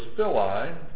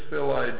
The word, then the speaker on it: filii: The second word was fili, F-I-L-I-I.